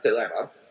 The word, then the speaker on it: Thelema: First of all, Telema, which I pronounce Telema, it's a Greek word, and I would say my pronunciation is ancient Greek,